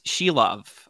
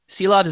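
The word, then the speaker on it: Silovs: Arturs Shilov, I believe. – Shilov. – Shilov, okay. – I think the last S is silent, but don't hold me to that. – And they throw a silent S at you after you get it.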